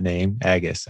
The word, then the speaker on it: Agus: I've been saying your name wrong, Agus. I'm finally reading. – That's right. – I've been sticking an N in there and there's no N in that name, Agus.